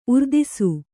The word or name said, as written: ಉರ್ದಿಸು